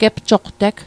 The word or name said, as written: gepsoqteg